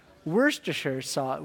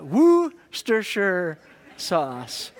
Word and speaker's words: Worcestershire: And then the hardest word that Bennett decided, and it's supposed to be pronounced, apparently, Worcestershire sauce. Worcestershire sauce. Worcestershire sauce. Huh. Apparently because of where it comes from in the UK, and then, I don't know why Shire gets sure, but that's apparently how you're supposed to say it.